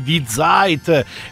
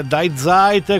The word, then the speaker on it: Die Zeit: Nel frattempo mi informano, grazie, grazie mille, che in tedesco il dittongo IE non si pronuncia AI, ma si pronuncia I, da cui di Zeit e non Die Zeit, come forse mi è capitato di dire, ma come diceva Paolo Conte, io non parlo il tedesco, scusa, pardon.